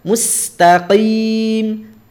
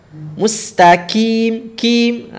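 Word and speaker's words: mustaqim (مُسْتَقِيم): qim nah, tidak usah terlalu berlebihan biasa mustaqim qi qi bukan qi qi qi ya bahkan ada yang sampai benar-benar supaya terlihat bawah itu ada huruf qaf terlalu berlebihan ya pengucapannya sampai qim gitu ya qim enggak itu berlebihan nas eh, mustaqim segitu cukup ya tapi hati-hati juga bercampur dengan huruf kaf Mustaqim, kim. Ah, salah. Tidak boleh. Tidak boleh masuk ke huruf kaf, ya.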